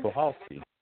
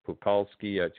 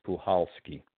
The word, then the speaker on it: Puchalski: You know, and the consensus was, and you know, some very knowledgeable people said it's the C is silent, so it's Pulhowski. It's not, because I was pronouncing it Pukalski to Pulhowski. I wasn't sure, but the C is silent, and the correct pronunciation is Pulhowski.